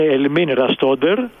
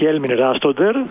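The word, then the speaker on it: Elmin Rastoder: Να διευκρινίσω ότι σύμφωνα με την ενημέρωση ΠΑΕ η κανονική προφορά του ονόματός του είναι Ελμίν Ραστόντερ και όχι Έλμιν Ραστόντερ.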